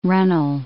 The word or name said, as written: runnel